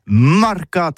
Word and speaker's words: marcats: Eh bien, d'ici à ça, tout tis, mais le mercat de l'Uni ou le mercat des rebelles, mais en gascogne toulousain, c'est prononcé sur les marcats, avec un A Marque.